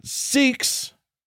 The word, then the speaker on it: Sikhs: Uh, it says uh, Sikhs. I, I purposefully sort of placed my vowel in the middle there.